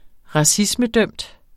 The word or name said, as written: racismedømt